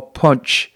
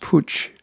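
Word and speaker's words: Putsch: In November 1923, after German government resumed the payment of war reparations to Britain and France, the Nazis launched the Beer Hall Punch, I guess it's pronounced Pooch, their first attempt at seizing the German government by force.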